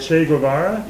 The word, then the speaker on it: Che Guevara: I've heard that one Catholic priest, I don't know the names and so forth, they made reference to Chavari, is that how they say it? – Che Guevara? – Yeah, Che Guevara, as the Christ figure.